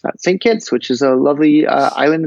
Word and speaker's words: St. Kitts: St. Kitts. – St. Kitts. – K-I-T-T-S, which is short for St. Christopher, but nobody says St. Christopher. They just say St. Kitts.